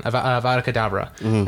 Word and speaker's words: Avada Kedavra: And then that night, Lord Voldemort tried to kill Harry with the spell, the forbidden spell, the dying, basically forbidden, Avada Kedavra.